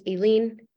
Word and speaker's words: Aleen: But my name is pronounced Aileen, like Aileen.